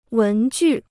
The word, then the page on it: 文具: wén jù